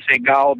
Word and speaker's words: Seagal: And then when Steven Seagal became popular, and he spelled his name that appears to be just plain Siegel, but he pronounces it Seagal, then you look at my name, and it looked like it should be Seagal, actually, but no, it's not.